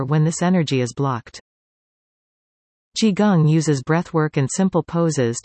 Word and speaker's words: Qigong: Qigong, pronounced Qigong,